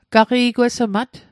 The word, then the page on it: gaqi'gwesmat: Mi'gmaq